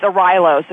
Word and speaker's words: Arylo: It's Arylo, so you're super close. – You're super close. Okay. Like with the rye bread, a rye loaf.